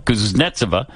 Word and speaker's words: Kuznetsova: Kuznetsova.